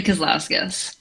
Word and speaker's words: Kazlauskas: Actually, Kazlauskas.